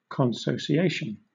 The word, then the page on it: consociation: British English